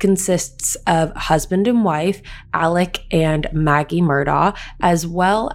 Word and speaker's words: Alex: But the way his name is spelt, you would think his name is Alex Murdaugh. However, his name is Alec Murdaugh.